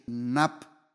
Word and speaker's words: naaph: Y en la Biblia la primera mención del adulterio es la palabra, se escribe N-A-A-P-H, nap, y aparece en los 10 mandamientos, Éxodo 20:14, no cometerás adulterio.